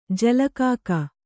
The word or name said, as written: ಜಲಕಾಕ